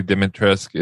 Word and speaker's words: Dimitrescu: It's Lady D- Dimitrescu.